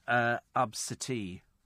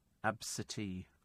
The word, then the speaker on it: Abcde: it's actually written down on her ticket as ABCDE, but it is, in fact, pronounced Absiti. Absiti.